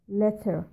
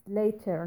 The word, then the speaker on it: later: You pronounced it like letter, means name. This one is more important than other words, because it changes the meaning of the word totally. So it's later, not letter.